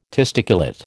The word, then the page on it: testiculate: American English